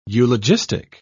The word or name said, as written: eulogistic